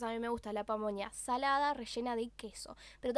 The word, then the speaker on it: pamonha: No sé si lo estoy pronunciando bien, se escribe P-A-M-O-N-H-A, pamonha. Viste que tienen como esta pronunciación medio nasal de la ñ al final de la garganta.